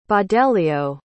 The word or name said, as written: baudelio